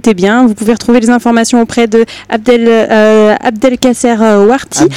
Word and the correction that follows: Abdel Nasser: Abdel Nasser, j'ai mal prononcé, excusez-moi.